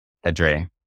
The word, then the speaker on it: Pedre: Pedre, yeah.